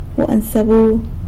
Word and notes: guan sebu: sanlucasquiavini

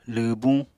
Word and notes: lwwbuñ: apatani

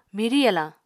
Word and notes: mirw yalañ: Apatani

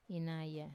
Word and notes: inaya: Wamesa

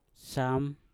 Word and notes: sam: mpur

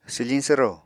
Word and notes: swfiñ swro: apatani

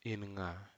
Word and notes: imiga: Aneityum

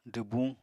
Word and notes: dwbuñ: apatani

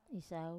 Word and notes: sau: Wamesa

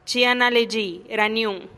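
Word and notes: chi a na lexi raniun: Teotitlán del Valle Zapotec

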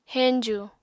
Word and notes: heñju: Apatani

